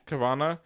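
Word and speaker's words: Carvanha: Carvana.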